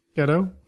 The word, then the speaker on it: Gadot: I'm not sure how to pronounce the last name. – That's right. – That's right. – Gadot. Mm-hmm.